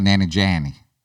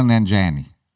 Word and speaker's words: Nanjiani: Kumail Nanjiani. – Du hast schon wieder ein I eingebaut. – Okay, das ist lustig. – Kumail Nanjiani.